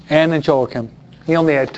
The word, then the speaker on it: Joachim: It's pronounced Joachim. Spanish called Joaquin. And Joachim is the way we say it in English.